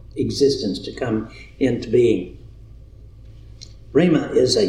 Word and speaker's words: rhema: This word is rhema. R-H-E-M-A, rhema, or pronounced rhema.